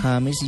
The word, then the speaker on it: James: se puede pronunciar de las dos formas. James y James igual está en el diccionario panhispánico de la lengua española y ahí lo podemos encontrar.